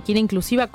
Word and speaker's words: kineinclusiva: la encuentran como @kineinclusiva, ¿lo dije bien? – Sí, muy bien, kineinclusiva con B corta.